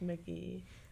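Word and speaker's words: Mk.gee: G-E-E. – G-E-E. – It's like pronounced Mickey.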